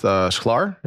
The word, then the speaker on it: Shklar: is Judith Schlar.